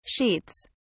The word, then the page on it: sheets: British English